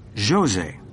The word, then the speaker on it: Jose: It's F-E-R-R-E-I-R-A. – And what's your first name? – Jose. That's J-O-S-E.